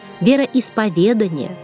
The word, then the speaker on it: вероисповедание: Хочу обратить ваше внимание, милые наши слушатели, на правильное произношение следующих слов. Запоминаем. Издревле – иконопись, знамение, вероисповедание, духовник. Наша передача «Уроки словесности» подошла к концу.